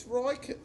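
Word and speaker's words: Räikkönen: What's it? What are you... It's Raikkonen. – Oh, right, okay. Raikkonen. – Can't you get the emphasis right? – No, I can't. – The right syllable.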